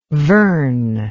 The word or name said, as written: vern